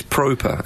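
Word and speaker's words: Pröpper: But they've signed the Dutchman, Davy, I think it's Proper, is how you pronounce it, but we will be calling him Proper.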